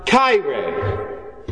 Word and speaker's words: chaire: The first word is Hail, which in Greek can be pronounced chaire. I might be pronouncing that depending on what era of Greek we're talking about. It's spelled with, like the English word chair, with an E at the end.